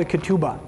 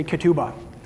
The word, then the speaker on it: ketubah: The Hebrew word for the marriage vows when they're written down, for like the marriage document, it's called a ketubah. Everybody say ketubah. And it's spelled K-E-T-U-B-A-H.